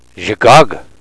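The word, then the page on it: zhigaag: Nishnaabemwin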